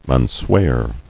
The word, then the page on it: Unswear: un·swear